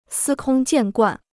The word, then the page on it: 司空见惯: sī kōng jiàn guàn